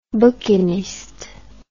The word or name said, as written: bouquiniste